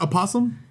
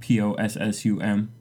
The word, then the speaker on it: opossum: Wait, is it not opossum? N- no, I learned it's just P O S S U M possum. But it's spelled with an O.